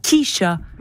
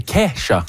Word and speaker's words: Kesha: Il y a Kesha aussi. C'est pas Kisha. – Non, c'est Kesha.